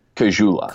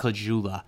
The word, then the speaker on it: Caggiula: What, what did you say? Caligula? Caggiula. Caggiula. Okay.